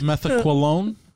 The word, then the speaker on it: methaqualone: Is that how we pronounce it? – Methaqualone. – Methaqualone.